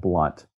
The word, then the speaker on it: Blount: It's Jeb, and the last name is, is pronounced Blunt. – Okay. – So it's like, spelled B L O U N T.